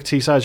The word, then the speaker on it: Teesside: And for those at Royal Holloway, Teesside is spelled T-E-E-S-S-I-D. It's Tees Side. – Yeah. – We're a town on the side of the River Tees. That's why we've got the name Tees Side.